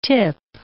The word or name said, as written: tip